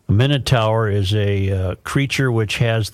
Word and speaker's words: Minotaur: Minotaur, am I pronouncing that correctly? – Minotaur. – Minotaur.